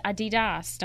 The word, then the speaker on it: Adidas: Adidas.